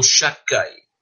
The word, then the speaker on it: Shaddai: Um dos nomes de Deus é Shin Dalet Yud, Shaddai. Ou nós pronunciamos ele melhor, Shakai.